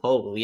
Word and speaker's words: Poeltl: How do you say that name? Jacob, the Spurs center. – Poeltl, yeah. Poeltl.